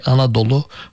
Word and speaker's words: Anadolu: And Turks adopted that and they pronounce it with a particular Turkish way, Anadolu, which means full of mothers, actually.